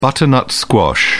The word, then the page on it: butternut squash: British English